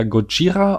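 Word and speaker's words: Gojira (ゴジラ): Godzilla wird wohl ähm, äh, im Japanischen eher Gojira ausgesprochen ähm,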